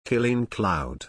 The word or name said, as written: Qilin Cloud